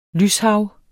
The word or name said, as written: lyshav